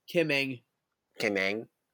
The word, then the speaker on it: Ng: Kim Ng.